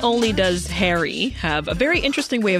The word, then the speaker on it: HXRY: And Harry, with it, which is, if anyone's looking him up, HXRY. – Remember that, HXRY. – Which is pronounced Harry.